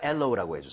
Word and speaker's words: aloe: 하지만 엘로우라고 해주세요.